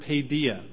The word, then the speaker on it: paideia: That word is translated from the Greek word paideia. That's spelled P-A-I-D-E-I-A. P-A-I-D-E-I-A. Paideia.